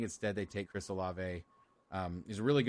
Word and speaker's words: Olave: Is it Olave? – Olave. – Amazing. Yeah. All right. I'm glad I got that right. I think they're going to take Chris Olave.